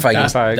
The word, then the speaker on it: Feige: you're going to have Kevin Feige or whatever his name is. What, mm-hmm. How do you say his name? – Kevin Feige. – That's correct.